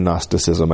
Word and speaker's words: Gnosticism: It's G-N-O-S-T-I-C-I-S-M. Gnosticism. So the G is silent. G-N-O-S-T-I-C-I-S-M, Gnosticism.